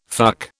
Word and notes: fuck: British English

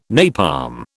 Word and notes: napalm: British English

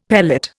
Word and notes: pellet: Indian

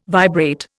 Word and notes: vibrate: Indian

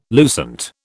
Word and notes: lucent: British English

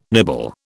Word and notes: nibble: British English

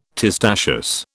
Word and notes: testaceous: British English

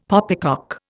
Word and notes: poppycock: Indian

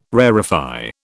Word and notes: rarefy: British English

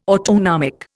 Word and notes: autonomic: Indian

